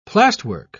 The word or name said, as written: plasterwork